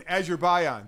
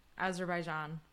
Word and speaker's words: Azerbaijan: All right, F1 is finally back in Azerbaijan. Did I say it right? – Oh, so close. – What is it? – Azerbaijan.